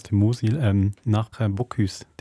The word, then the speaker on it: Bocuse: Ich habe sogar falsch ausgesprochen. Ich habe es nicht nur falsch verstanden oder gar nicht verstanden. bocuse Ja.